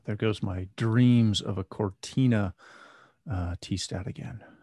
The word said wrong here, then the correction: Cortana: Cortana. – So, Cortana. See, can't even pronounce it right.